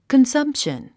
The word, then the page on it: consumption: kənsʌ́mpʃən; カンサンプション